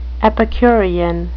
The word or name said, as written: epicurean